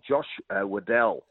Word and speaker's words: Weddle: There's a kid called Josh Weddle, in fact, it'd be the correct pronunciation, a boy that was picked in that All-Australian side that really is physically ready to go.